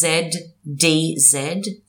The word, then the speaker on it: Zdzisław: There's a lot of Z's in there. His first name is Zdzisław. Surname Beksiński. Beksiński.